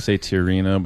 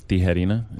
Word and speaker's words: Tijerina: I don't even think I say it right, but I say Tijerina, but it's Tijerina in Spanish.